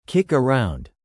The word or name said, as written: kick around